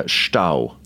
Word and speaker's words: Stau: It's one word with one syllable, and it's called Stau.